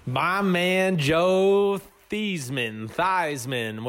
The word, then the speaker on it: Theismann: And I had no knowledge of how the Joe Theismann, you know, rhyming with Heisman at Notre Dame came about.